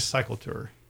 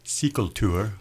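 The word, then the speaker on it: cykeltur: It's a gravel ride, and it's called Cycle Tour, 3/4 minus Cycle Tour. – Yes, and Cycle Tour, actually the pronunciation is Cykel Tour, which is Danish for bike ride.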